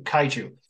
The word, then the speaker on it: kaiju: So when you start getting these different monsters coming in, and you'll have to help me with the pronunciation, because the Japanese word for them is Kajuru, is that right? – Kaiju.